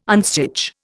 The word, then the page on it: unstitch: Indian